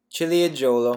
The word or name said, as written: ciliegolo